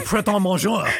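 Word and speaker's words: Pret A Manger: It's actually Pret-A-Manger.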